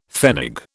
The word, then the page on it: pfennig: British English